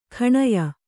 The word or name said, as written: ಗಣೆಯ